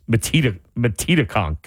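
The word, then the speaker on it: Metedeconk: And I think I was saying, like, you know, Metetaconk. And then a caller had to call in off air and say, no, it's Metetaconk.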